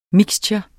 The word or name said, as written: MIXTURENE